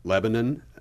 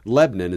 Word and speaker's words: Lebanon: Lebanon is where you would pronounce the major city overseas, but Lebanon is how you pronounce the town here in Missouri.